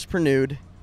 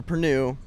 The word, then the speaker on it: Perrenoud: So my last name's Pernude, or Pernue, but people pronounce it Pernude.